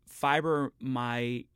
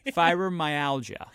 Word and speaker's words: fibromyalgia: Fibromyalgia. Did I say that right? No, I did not. Fiber, what is it? Don't laugh at me. Fibromyalgia.